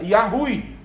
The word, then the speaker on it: Yahweh: Poderia, talvez, na mente do povo, ser o próprio Jeová, ou, como é a melhor pronúncia, Yahweh, né?